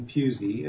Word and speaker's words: Pusey: Yes, it's Stephen Pusey. It's the last name is pronounced as if it has a Z in it. The spelling there is S-T-E-P-H-E-N, and my last name is spelt P-U-S-E-Y.